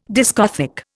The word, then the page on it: discothèque: Indian